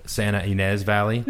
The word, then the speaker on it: Santa Ynez Valley: I'll probably pronounce most of your towns wrong, but the Santa Ynez Valley. – No, you got it right.